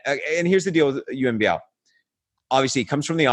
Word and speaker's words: Yuen Biao: And so my number five is Yuen Biao. – Nice. – AKA Yuen Biu, if you want to use the Cantonese pronunciation, as you probably should.